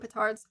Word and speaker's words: petards: Then there's Hoist on Their Own Petards. Is that how you pronounce that word? – I'm going to say it's yes.